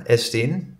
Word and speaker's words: Estien: Je artiestnaam is Estien, hè? Ja. Dat moet ik ook zo uitspreken, hè? Estien.